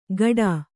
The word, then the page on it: ಗಡಾ: Kannada